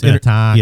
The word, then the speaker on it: Minnetonka: It's pronounced Minnetonka. Yeah. – Minnetonka.